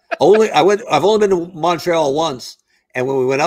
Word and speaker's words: Montreal: Montreal. – All right. – And you even pronounced it right. I hate when it's like a Montreal. It's like, that's Montreal.